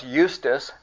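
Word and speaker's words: Justus: And they proposed to Joseph, called Barsabbas, who was surnamed Justice, right? Pronounced Eustace, and Matthias.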